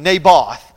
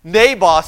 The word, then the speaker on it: Naboth: Nabab. Nabab. I just mispronounced that name, and I realized that, so I want to go back and fix this one. Naboth. Naboth sees his vineyard and says, "I want that vineyard," and says, "I want to buy that."